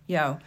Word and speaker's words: Yeoh: I think it's Michelle Yeoh. – Yeoh.